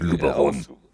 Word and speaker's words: Luberon: Da kann man was mit anfangen und ähm, dann gebe so zum, zum Graveln tatsächlich eher den, ich sage jetzt mal Lübro, ich hoffe, es ist richtig ausgesprochen. Ähm, auf Deutsch, ich, Luberon. – genau, auf Deutsch, Luberon.